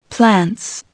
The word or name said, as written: plants